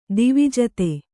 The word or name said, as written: ದ್ವಿಜಾತ